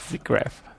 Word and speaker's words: SIGGRAPH: SIGGRAPH.